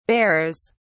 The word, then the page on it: bearers: British English